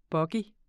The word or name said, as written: bogie